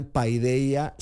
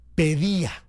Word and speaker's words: Paideia: En realidad, los puristas no dirían Paideia, sino Pedía, que es pues la pronunciación griega, pero está bien.